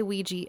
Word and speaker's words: Ouija: So I will never say Ouija ever again because they were very like, it is Ouija and you will say it like that.